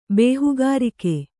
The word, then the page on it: ಬೇಹುಗಾರಿಕೆ: kannada